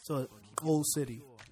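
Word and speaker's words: Xoul City: But it's spelled X-O-U-L and then City. And so when you say an X in most African dialects, you would say so Old City.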